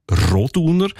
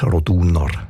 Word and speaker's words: Roduner: Also üblicherweise betonen wir ja Namen in der Deutschschweiz, wo drei Silben haben, immer auf der ersten Silbe. Also Andereck, Zollinger, Bertschinger, Ochsenbein. In dem Fall ist es aber eher die zweite Silbe, die betont Roduner.